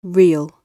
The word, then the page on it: real: British English